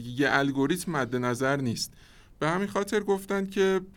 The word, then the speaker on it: NIST: به نام NIST یا نیست که حالا تو فارسی هم تلفظش راحته